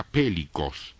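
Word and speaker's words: pelikos: La palabra grande aquí es la palabra pélicos.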